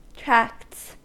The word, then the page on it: tracts: American English